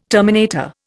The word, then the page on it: terminator: Indian